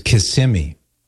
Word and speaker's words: Kissimmee: I'll never go back to my wayward days of saying Kissimmee. It's Kissimmee. Or I've been adamantly corrected on that. So Kissimmee, Florida.